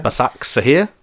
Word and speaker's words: Başakşehir: Is that how you say it? – Başakşehir. – I think Simon was close enough. – I was. – Başakşehir. – It's not easy. – It's definitely not got a T in it.